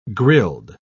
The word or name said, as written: grilled